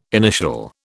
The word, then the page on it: initial: British English